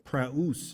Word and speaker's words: praus: or the root word there is praus. It's P-R-A-U-S. Praus is how it's pronounced, which means meek.